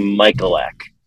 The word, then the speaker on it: Michalek: but it is actually Michalek.